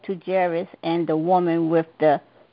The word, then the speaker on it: Jairus: The topic of the lesson is Jairus, that's how I pronounce his name, daughter and the bleeding woman.